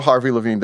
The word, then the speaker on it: Harvey Levin: am i getting his name right harvey levin is that his, uh, is that how you say his name that's right yeah Harvey Levin.